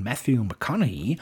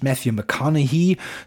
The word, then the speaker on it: Matthew McConaughey: So wird es, glaube ich, ausgesprochen, Matthew McConaughey.